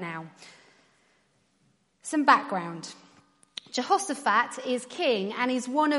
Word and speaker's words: Jehoshaphat: Jehoshaphat.